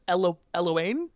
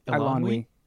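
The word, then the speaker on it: Eilonwy: It was because even, and I'm going to mispronounce her name, the Princess Eloane? – Elanwi.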